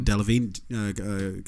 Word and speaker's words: Delevingne: Delevingne.